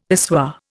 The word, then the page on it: pissoir: Indian